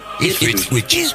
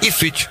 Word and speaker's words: Ipswich: ¿Cómo se dice? – Ipswich. Ipswich.